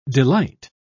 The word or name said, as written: delight